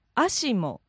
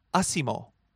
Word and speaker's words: ASIMO: Ashimo. – And I believe the English pronunciation would be Asimo. Now, it's actually A-S-I-M-O, which stands for Advanced Step in Innovative Mobility.